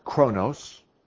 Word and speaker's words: chronos: This word's pronounced chronos. This one is kairos.